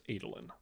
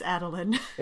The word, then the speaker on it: Adolin: And yes, it is pronounced Adolin. – Says you. – No. No, it's not. It's Adolin.